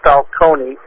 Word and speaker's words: Falcone: It's Tony, C-O-N-Y, and then Falcone, F-A-L-C-O-N-E.